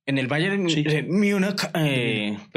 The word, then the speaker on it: Múnich: ¿Cómo es que se pronuncia? Múnich. Ok, Múnich.